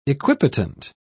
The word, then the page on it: equipotent: British English